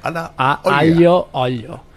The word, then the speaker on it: Aglio olio: Aglio olio.